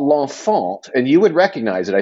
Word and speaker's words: L'Enfant: Okay, so it's L'Enfant. So it's L apostrophe E N F A N T. So the baby in French, in La Francais. L'Enfant poster. – L'Enfant.